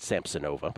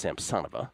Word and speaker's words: Samsonova: I used to pronounce it Samsonova, but I'm told it's Samsonova.